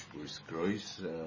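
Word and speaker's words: Boris Groys: تلفظ درست درش بوریس گرویس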